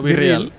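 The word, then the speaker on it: BeReal: Virril, ¿qué es eso de virril, tío? – ¿Virril o virreal? – Virreal, Virreal. Hostia, estoy pronunciando hoy que parezco de...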